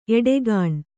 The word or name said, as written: ಎಡಗಣ್